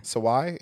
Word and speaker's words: Sawai: But Anna Sawai. – Yeah, Anna Sawai deserves every single award next year.